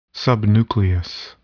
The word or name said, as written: subnucleus